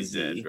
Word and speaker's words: Z: Is it Zed or is it just Z? – Well, it's Greek. It's usually so just say Zed, right? – Yeah. – It's, just about everybody but Americans say Zed. – Yeah. – So. Okay. – And specifically in the film, they pronounce it Zed.